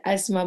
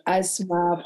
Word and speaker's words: Asmaa: Et en fait, euh, il y a le asthma sénégalais, il y a le asthma marocain, il y a le asthma français. Euh, chacun euh, prononce comme il veut.